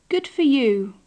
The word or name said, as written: good for you